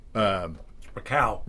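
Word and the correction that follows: Rakau: Only it's Rocco. Not, Rocco. – It's like Rocco. – It's like Rocky.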